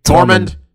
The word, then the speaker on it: Tormund: Tormund.